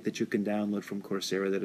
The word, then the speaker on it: Coursera: What's funny about, again, the organization is Coursera, which is spelled like course, C-O-U-R-S-E, with an R-A at the end, Coursera.